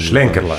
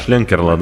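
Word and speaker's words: Schlenkerla: Э, есть в Бамберге замечательная такая пивоварня, называется Эх Шленкерла или Шленкерла, как там? – Шленкерла. Шленкерла, да.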